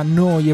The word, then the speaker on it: Neu: Siete all'ascolto di Noi Radio, neuradio.it, si scrive Neu, si pronuncia Noi e vuol dire nuova emittente urbana.